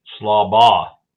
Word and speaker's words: Schlabach: Schlabach. – Oh. – No K on the end of it.